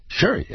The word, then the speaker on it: Shari: That's Sherry spelled S-H-A-R-I, but pronounced Sherry, as in have a very merry Sherry's Berries Christmas.